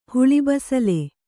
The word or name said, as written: ಹುಳಿಬಸಲೆ